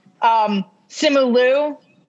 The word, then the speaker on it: Simu Liu: She's dating Shang-Chi, aka Simu Li. – Oh, wow. – I think I might be saying his name wrong. Simu Liu.